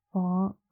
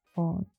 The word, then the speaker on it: Vaughn: Vong. I feel like an actor has this name. It's like V, not like, it is V-A-U-G-H-N. Vong.